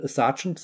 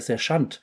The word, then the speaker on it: Sergeant: Er sagt dann zum Beispiel, Sergeant sagt er Sergeant.